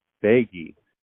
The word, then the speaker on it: Beigi: How do I say your last name? – Beggy. – Beggy. – Yes. – Miriam Beggy over at UCLA.